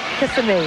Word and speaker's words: Kissimmee: Kissimmee, Florida? Is that the way you pronounce it? – Kissimmee. – What about at night? – Kissimmee.